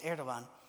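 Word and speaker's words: Erdoğan: Or last week, the head of Turkey, Erdogan, it looks like Erdogan, but it's pronounced Erdogan,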